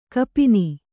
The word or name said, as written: ಕಪಿನಿ